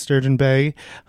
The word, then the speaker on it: Sturgeon Bay: So after high school, after you were a Clipper at Sturgeon Bay, did I say that right? – That's correct.